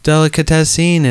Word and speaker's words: Delicatessen: If you haven't, I think that's Delicatessen. – Oh, Delicatessen. – Delicatessen. – Delicatessen is actually how it's pronounced.